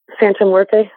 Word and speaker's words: Santa Muerte: And I how do you pronounce it? Santa Muerte? Is that how you pronounce it? – Santa Muerte.